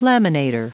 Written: laminator

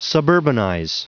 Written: suburbanize

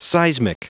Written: seismic

